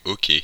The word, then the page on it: hockey: France (Normandie); [o.ke]